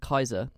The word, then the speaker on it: Caesar: It's like Kaiser.